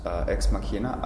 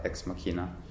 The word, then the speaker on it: Ex Machina: It's pronounced Ex Machina, I think, in Latin.